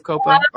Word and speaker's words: COPPA: Is COPPA the right way to say it instead of COPA? – I think you can go either way.